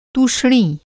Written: तूष्णी

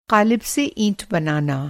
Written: क़ालिब

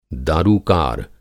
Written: दारूकार

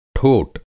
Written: ठोट

